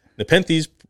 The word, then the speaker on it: Nepenthes: Nepenthes, which is pronounced Nepenthes, is a genus of pitcher plants typically found in Southeast Asia, at Australia and Madagascar.